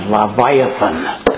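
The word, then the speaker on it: Leviathan: 英語で言えばラバイアタンという発音ですね。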